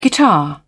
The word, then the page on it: guitar: British English